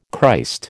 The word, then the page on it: Christ: American English